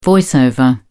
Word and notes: voice over: British English

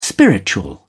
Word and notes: spiritual: British English

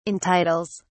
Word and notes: entitles: British English

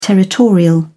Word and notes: territorial: British English

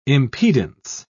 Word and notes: impedance: British English

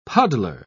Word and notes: puddler: British English

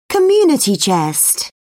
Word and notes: community chest: British English

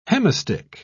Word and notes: hemistich: British English

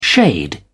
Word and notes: shade: British English

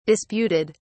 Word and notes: disputed: British English

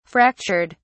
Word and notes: fractured: British English

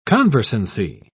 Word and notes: conversancy: British English

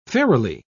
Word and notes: fairily: British English

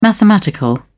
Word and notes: mathematical: British English

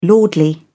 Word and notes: lordly: British English

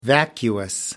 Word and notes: vacuous: American English

